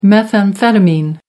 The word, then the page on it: methamphetamine: American English